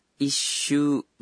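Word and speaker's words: isu (一周): Jadi pengucapannya isu bukannya ichishu ya? – Betul sekali.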